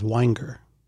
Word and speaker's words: Weinger: And it's a soft G. The G isn't even there. It's Weinger. You just kind of touch upon the G and you jump right off, right? – The family preference is Weinger. – So it's a hard G.